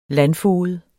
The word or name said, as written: landfoged